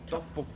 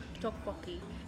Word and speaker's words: tteokbokki: Tteokbokki. – Tteokbokki.